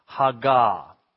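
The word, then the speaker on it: Haggah (הגה): Now here's your second Hebrew word for the day. Haggah. Everybody say Haggah. You can spell it H-A-G-A. Haggah. It's the word that's translated meditate.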